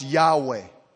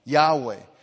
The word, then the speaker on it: Yahweh: See, the first capital letters is the word that most scholars now pronounce Yahweh. Okay? Yahweh. And this word,